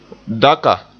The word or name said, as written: daka